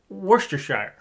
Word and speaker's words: Worcestershire: Worcestershire. – You saying it right? – Yeah. – That is a very commonly screwed up word, right? – Yeah.